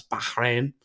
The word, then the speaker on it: Bahrain: Bahrain, as they pronounce it. – Uh, mm. I know that because there's a Grand Prix there, and, and they make a great emphasis on pronouncing it as Bahrain.